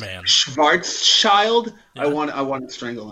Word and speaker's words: Schwarzschild: Schwarzschild. – Schwarzschild. – Schwarz, like black? – Schwarz. – Schild. – Schild. – Not child.